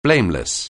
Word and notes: blameless: anglais

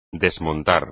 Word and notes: desmontar: espagnol

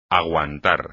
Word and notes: aguantar: espagnol

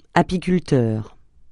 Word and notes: apiculteur: francais

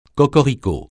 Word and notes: cocorico: francais

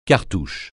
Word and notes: cartouche: francais

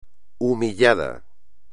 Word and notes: humillado: espagnol

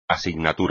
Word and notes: asignatura: espagnol

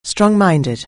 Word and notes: strong-minded: anglais

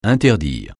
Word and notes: interdire: francais

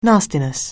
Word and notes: nastiness: anglais

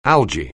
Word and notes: algae: English

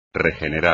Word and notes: regenerar: espagnol

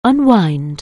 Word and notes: unwind: anglais

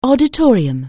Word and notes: auditorium: anglais

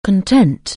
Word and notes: content: anglais

